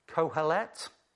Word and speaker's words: Kohelet: And this is a Hebrew word that's been translated Kohelet. You don't pronounce the H, so Tim tells me. It's good to be able to ask people these things. Kohelet, it means some.